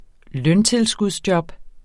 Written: løntilskudsjob

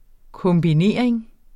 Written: kombinering

